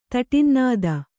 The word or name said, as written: ತಟಿನ್ನಾದ